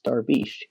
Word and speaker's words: Darvish: The correct pronunciation is Darvish. His father's Iranian.